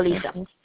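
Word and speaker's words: Alyssa: Alisa.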